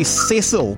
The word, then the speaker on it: Cecil: I would say Cecil, but sometimes Americans say Cecil. – Americans are wrong.